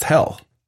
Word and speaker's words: Hel: The town is it's spelled H-E-L, but it's pronounced hell, right? Mm-hmm. Hell.